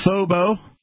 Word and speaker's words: Phobo: We've been talking about Phobo, rhymes with hobo.